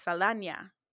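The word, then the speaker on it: Saldaña: her credit name is spelt with an ñ, so it's Saldana.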